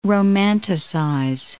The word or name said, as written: romanticize